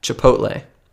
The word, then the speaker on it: Chipotle: You're transposing letters, and as far as I know, most people would pronounce it Chipotle, right? I'm sorry, you are not transposing the O and the L, you are transposing the T and the L.